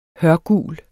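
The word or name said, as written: HØRGUL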